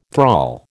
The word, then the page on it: thrall: American English